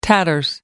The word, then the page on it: tatters: American English